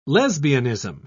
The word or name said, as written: lesbianism